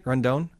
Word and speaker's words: Rondon: Am I saying that right? Rondon? – Yes. Yeah.